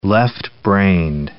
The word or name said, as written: left-brained